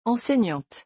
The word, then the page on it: enseignante: French